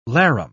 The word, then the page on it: larum: British English